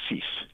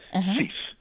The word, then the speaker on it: Seeff: We're called all sorts of things, hopefully most of them positive, but the correct pronunciation is Seeff. – It's what? Seeff? – Seeff, yes. – Okay, so you don't actually, you pronounce the F as an S? – No, S as in for sugar, Seeff.